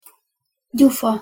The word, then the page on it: gyufa: Hungarian